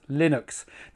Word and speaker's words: Linux: Number 5, Linux is always pronounced Linux.